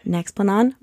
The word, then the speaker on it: Nexplanon: Nexplanon, right, which was approved in Canada.